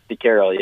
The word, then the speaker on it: De Keyrel: Yeah, it's DeCarroll.